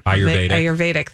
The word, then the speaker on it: Ayurvedic: How do you say that? – Ayurvedic. – Ayurvedic, thank you.